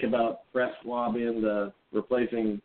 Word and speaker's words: Francois: Is it, how do you pronounce his name, Francois? – DeAndre Francois.